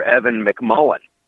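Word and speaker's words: Evan McMullin: They could go for Evan McMullen. Or I got the name right, I hope. – You did. You nailed it.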